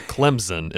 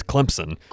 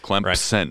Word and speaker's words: Clemson: And you have to say it properly with a P in the middle. It's not Clemson. It's Clemson. – Clemson.